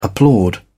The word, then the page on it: applaud: British English